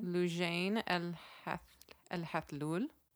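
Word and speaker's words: Loujain al-Hathloul: Is it Lujan Al-Hathloul? Can you spell her? Can you say her name? – Lujain Al-Hathloul. – Oh, that sounds so much better than mine.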